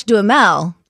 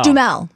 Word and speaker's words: Duhamel: Well, we've always said Josh Duhamel. – No, we don't say Duhamel. – Josh Duhamel. – Duhamel. – Josh Duhamel, Tyga. – Hi, my name is Josh Duhamel. – Duhamel. – Duhamel. – Duh, not Duh. – Duh, Duh, same thing. – It's pretty close.